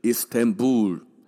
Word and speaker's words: Istanbul: 伊斯坦堡这个城市的发音叫做 Istanbul，发音比较像是伊斯坦布尔这样子的翻译啦。